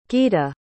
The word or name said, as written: geeta